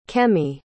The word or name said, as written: kemi